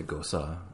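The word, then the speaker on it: Gose: You say gosa. – Gosa. That's the German pronunciation. What about goze? Goze. I um, don't know if that's right. um, but when there's an e at the end of a word in German, it's like a uh, sound.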